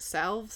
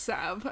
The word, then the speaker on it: salve: Salve? Salve? Some people say salve. Some people say salve. – I think it's Sav. I think the L is silent.